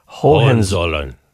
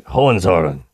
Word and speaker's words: Hohenzollern: But Hohenzollern. – There you go. That's it. Hohenzollern.